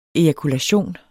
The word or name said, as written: ejakulation